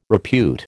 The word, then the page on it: repute: American English